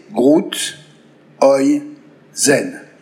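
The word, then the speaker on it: Groethuysen: comme l'a été et l'est encore Bernard grootheusen prononcé Groot, Oi, Zen, plus familièrement Groot.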